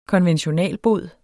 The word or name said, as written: konventionalbod